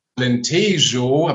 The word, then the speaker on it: Alentejo: genauer gesagt in die Region Alentejo, habe ich eben noch gelernt, wie ich das richtig ausspreche, ausgewandert.